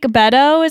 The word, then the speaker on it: Beto: Did I say that right this Beto. – Time? – Yeah, yes, yeah, you did. Me. Yeah. – Do you think Beto is gonna say it? – Yeah.